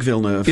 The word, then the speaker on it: Villeneuve: director Denis Villeneuve. If you could, is that how you pronounce it? It's how I'm pronouncing it's it. It's um, definitely. Oh, okay. Oh, I'm going with as in Jacques Villeneuve.